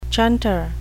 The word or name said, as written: chunter